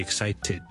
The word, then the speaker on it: excited: 听一次，I'm really excited。听唔听到？我感到非常之兴奋。I'm really excited。最尾要 ted 之后翻出嚟咧，我先至一个完整嘅发音嚟嘅。 So I'm really excited，或者我好感到兴趣。